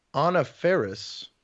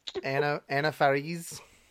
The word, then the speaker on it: Anna Faris: Who you'll be connecting to, Anna Faris. – Okay. – As I think she prefers for her name to be pronounced, but I don't know her personally. – Anna Faris.